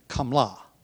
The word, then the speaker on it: qamla: And if we're going to spell them phonetically, the Aramaic word for gnat, it would be spelled Q-A-M-L-A with accent on the last syllable, kamla. While the Aramaic word for camel would be spelled the same way, only substitute for the Q at the beginning, a G, gamla.